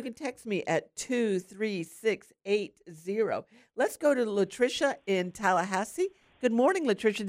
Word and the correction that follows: Letitia: No, it's Letitia. – Letitia, there you go, hon.